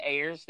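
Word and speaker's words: Ayres: I think it's Ayers. That's how it's pronounced.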